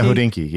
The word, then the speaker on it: Hodinkee: Yeah, Hodinkee.